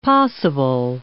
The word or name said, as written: possible